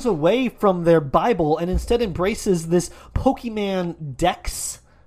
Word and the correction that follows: Pokédex: Am I saying that right? – Pokédex. – Pokédex.